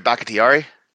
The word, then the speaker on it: Bakhtiari: Say his name again. – David Bakhtiari. – Way better that time.